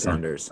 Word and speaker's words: Saunders: Is that how you say it? Sanders? – Saunders? I think it's Saunders. It's Saunders.